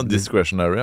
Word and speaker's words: discretionary: Og jeg måtte google hva discretionary, eller hva søren det uttales som, er. For jeg trodde det bare var diskret. – Altså, det, ja, discretionary, ja. Altså at your own discretion.